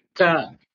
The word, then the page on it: k: Sanskrit